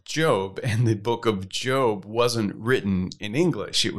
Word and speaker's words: Job: wants to know, how do we know that it's Job and not Job? Caleb, in English, this is quite a perplexing challenge, because in any other circumstance, seeing the word spelled J-O-B, you would pronounce it job, as in you're going to work because you have a job.